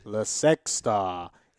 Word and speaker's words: La Sexta: La Sexta is how they pronounced it.